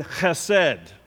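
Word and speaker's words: chesed (חֶסֶד): You got to do the guttural sounds if you're going to say it right. The word chesed. Just try that if you want. Chesed. You know, you get a little phlegm coming up. That's when you know you're doing it right.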